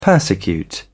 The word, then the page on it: persecute: British English